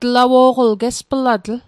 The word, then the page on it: gesplatl: Mi'gmaq